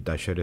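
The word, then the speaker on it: Talleres: Άρα είναι ε, τασέρε. Έτσι, έτσι, έτσι, την προφέρουν οι Αργεντίνοι. Ο Ισπανό προφέρει την άλλη.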